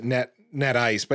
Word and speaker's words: NetYCE: Itential, Glueware, Net, I think they pronounce it NetIce, but NetYCE, Anuda Networks, Apstra.